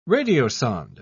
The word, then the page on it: radiosonde: British English